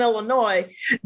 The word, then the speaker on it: Illinois: Because it seems to me that bungee jumping would be much more applicable here in California than in Illinois. Or as some people would say, Illinois, which I want to hurt them. They do Illinois, right? – Hell no. That S is silent forever and always.